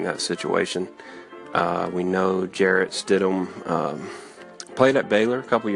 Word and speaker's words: Stidham: Monday, in the least surprising announcement in the history of Auburn football, Coach Gus Malzahn announced that Jarrett Stidham, and it is pronounced Stidham, will be the quarterback of Auburn.